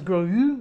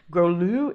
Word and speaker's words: Grolleau: I always thought it was Gros. Grolu, it's spelled Grolleau.